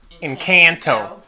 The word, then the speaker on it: Encanto: The correct way to say it, not the American way, Encanto.